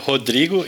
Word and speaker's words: Rodrigo: It, it, it, it's uh, Rodrigo in Portuguese, but usually uh, American call Rodrigo, but uh, you can say in, uh, in the way you want better.